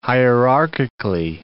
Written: hierarchically

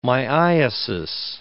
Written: myiasis

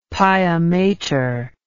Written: piamater

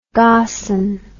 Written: garson

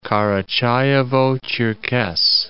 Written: Karachayevo-Cherkess

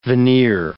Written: veneer

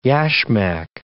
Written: yashmak

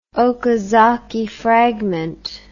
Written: okazaki fragment

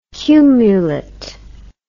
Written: cumulet

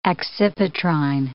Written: accipitrine

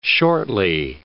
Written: shortly

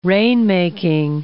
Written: rainmaking

